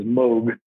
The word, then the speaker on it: Moog: It's like Moog synthesizer instead of Moog. Like we want to say Moog, but everyone I know in music and in the Netherlands says Moog.